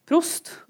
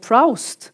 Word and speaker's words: Proust: og det går opp for henne at man uttaler prost prost, og ikke proust, mm.